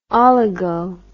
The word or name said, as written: oligo